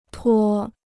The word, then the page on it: 脱: tuō